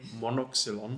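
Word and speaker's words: monoxylon: So you tell me, how I pronounce it properly. – Monoxylion. – Thank you.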